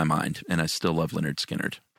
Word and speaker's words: Lynyrd Skynyrd: Next up, Lynyrd Skynyrd's first record, pronounced Lynyrd Skynyrd.